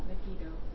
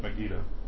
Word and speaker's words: Megiddo: In Israel, they pronounce that Megiddo. – Right, Megiddo.